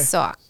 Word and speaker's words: suak: S-U-A-K. – Okay. – Soak. And that's Inuit, North Slope Inuit spelling. There's different dialects. But I feel like I was born to be soaked.